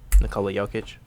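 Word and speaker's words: Nikola Jokić: Nikola Jokic. – Jokic.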